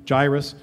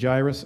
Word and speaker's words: Jairus: This, by the way, is two pronunciations for him, Jairus and Jairus. I'm going back and forth, not trying to confuse you.